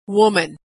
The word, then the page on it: woman: /w/